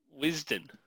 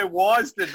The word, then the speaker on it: Wisden: And you mispronounced it. It's Wisden. – Why, shut up. No, you mispronounced the mispronunciation. – Oh, did I? – You're supposed to say Wisden.